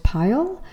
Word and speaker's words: Payal: I'm your host, Payal. Like a pile of cash,